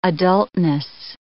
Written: adultness

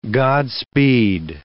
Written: Godspeed

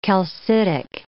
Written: calcitic

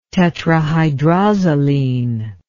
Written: tetrahydrozoline

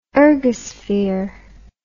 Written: ergosphere

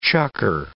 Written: chukker

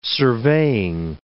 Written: surveying

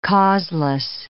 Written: causeless

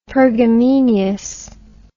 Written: pergameneous

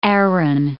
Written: Aaron

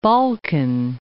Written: Balkan Mountains

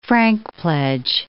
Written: frankpledge

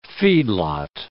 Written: feedlot

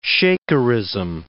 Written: Shakerism

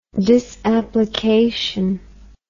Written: disapplication

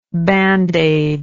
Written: bandaid

